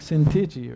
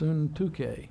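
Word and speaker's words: Syntyche: It looks like Sintichi or something, but the way you pronounce Suntuke.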